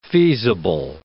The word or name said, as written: feasible